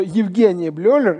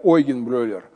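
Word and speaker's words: Eugen Bleuler: Но дело в том, что Евгений Блёлер, или правильно говорить, Ойген Блёлер, он как раз и говорил о том, что это группа заболеваний, которая различается по тяжести, по прогнозу, по клиническим проявлениям.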